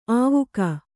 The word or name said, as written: ಆವುಕ